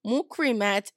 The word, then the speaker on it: Mukrimat: It has been a funny one because, okay, granted, you know, I was born Mukrimat, right? Mukrimat is a Arabic name. If properly pronounced, it would be Mukrima, right?